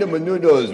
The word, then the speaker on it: Menounos: Benina Menounos with the backyard, the fire pit, nice. – Yeah, she pronounces her name Maria Menounos.